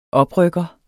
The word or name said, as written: oprykker